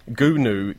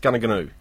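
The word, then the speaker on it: Goonoo Goonoo: Where's Gunu Street? And they all said, oh, say it. Apparently it's pronounced Gunaganoo.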